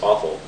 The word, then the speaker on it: offal: Another one is I explore the food markets of Chinatown, where they have amazing what's called offal meats, OFFAL, which is pronounced awful, and it's like kidney and tripe, which is the lining of the cow's third stomach, and brain and pig uterus.